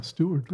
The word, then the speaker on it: Steward: Yeah, Stewart with a D. – S-T-E-W-A-R-D, Stewart.